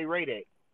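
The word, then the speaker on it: Hradec: Radek is spelled H-R. A D E C, I think. I know it got an H and an R at the beginning. And I know it say Radek, but I don't know exact spelling.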